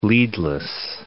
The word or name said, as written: leadless